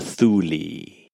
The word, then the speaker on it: Thule: Is it Thule or Thule? – Well, it's spelt T-H-U-L-E, but it's pronounced Thule. – Interesting. – Yeah, it's a Greek thing.